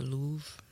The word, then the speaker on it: Louvre: Is it the Louvre? Mm-hmm. Yeah, I'm probably saying it wrong. But no, you got it right, as far as I know.